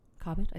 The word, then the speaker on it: Cobbett: Cobbett, I said that right? – You did.